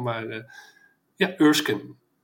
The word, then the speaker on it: Erskine: De Donald Glover remake reboot van Mr. en Mrs. Smith met uh, tegenspeelster Maya Erskine Ursken zeg ik dat goed? – Erskine, ja, ik had hem nog even gegoogeld.